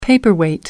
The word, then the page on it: paperweight: American English